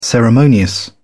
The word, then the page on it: ceremonious: British English